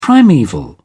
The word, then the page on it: primeval: British English